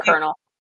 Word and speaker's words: Colonel: Oh gosh, he was this big colonel. – C O N. It's spelled not how it sounds. – I know, and that's why I can't spell it. I'm Googling. – C-O-L-O-N-E-L, Colonel Custer.